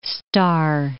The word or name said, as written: Starr